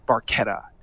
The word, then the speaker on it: Barchetta: It's Barchetta. That's how it should be pronounced in Italian. But of course, these guys are all Canadians, and so like Barchetta to them. No, it's Red Barchetta. It's like a barca.